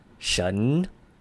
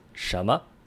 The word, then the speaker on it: 什么: But in this special scenario, you don't actually pronounce it 神, you just drop that N and bring it together with the second syllable, and that's 什么.